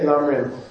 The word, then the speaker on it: Lam Rim: You should know that Lam Rim, say Lam Rim. It's like one of the only words in Tibetan that's easy to pronounce.